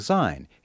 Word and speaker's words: Syne: And some expert advice from a Scot about the proper pronunciation of the last word of the phrase, Auld Lang Syne. He says, say the S, S-I-G-N, the way you pronounce it.